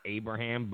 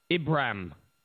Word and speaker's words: Ibrahim: And I know I'm saying Abraham and it's not Abraham, but Ibrahim. Isn't that how you say it? Ibrahim. IBRAHIM. Ibrahim